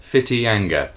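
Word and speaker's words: Whitianga: To the nearest cinema, the Mercury, in Whittyanga. It's pronounced Fittyanga. Okay, Fittyanga. We chose Paddington 2, 10 Chris's.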